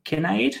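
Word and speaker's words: Kinnard: Dalton Kinnaid, is that how you say it? – Yeah. – Yeah. Sounds fine.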